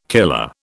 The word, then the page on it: killer: British English